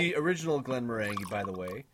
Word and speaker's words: Glenmorangie: We are drinking Glenmorangie, as I've been told it's pronounced.